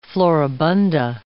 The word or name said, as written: floribunda